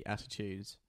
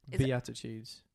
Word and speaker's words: Beatitudes: It's Plan B, attitudes. – Beatitudes. – Beatitudes. – Attitudes. – It's spelled Beatitudes. – Attitudes. – Beatitudes. Not attitudes. That's double T.